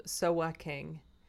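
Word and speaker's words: Sowaking (소확행): Sowaking, which is my Americanized pronunciation, though they told me it was an excellent pronunciation.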